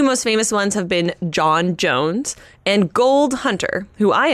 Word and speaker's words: J'onn J'onzz: So his name is John Jones, and I am pronouncing it like that because that is the way that Andrew Kreisberg and Jeff Johns do it, so I trust them. It is spelled J-O-N-N, J-O-N-Z-Z.